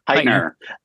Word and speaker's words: Hytner: It is the Nicholas Hytner, is that how you say it? – Hytner